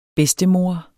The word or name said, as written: bedstemor